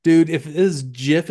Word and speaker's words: GIF: So, anyways, yeah, GIF is pronounced GIF. – Sure. – Oh, okay. G I pronounce it heef, like the Spaniards intended to.